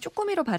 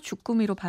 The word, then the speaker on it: 주꾸미: 많은 분들이 쭈꾸미로 발음하는데 맞춤법상으로 주꾸미로 발음하고 적게 돼 있습니다.